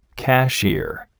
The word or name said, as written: cashier